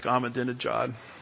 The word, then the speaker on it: Ahmadinejad: And the former leader of Iran, Ahmadinejad, How do you say Ahmadinejad? Just say genocide really fast, and it sounds like Ahmadinejad.